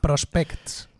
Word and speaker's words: Prospects: Prospects, lo pronuncio fatal. – Prospects, ¿vale? Me he querido pasar de, acento y me ha salido mal.